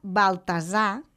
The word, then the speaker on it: Baltasar: I el Baltasar s'anomena Baltasar, fent la S sonora i també sense pronunciar la R final.